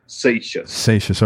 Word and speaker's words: Seixas: Satius is S-E-I-X-A-S. Satius.